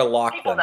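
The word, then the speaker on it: Loughlin: It's Laura Laughlin. That's how you say it.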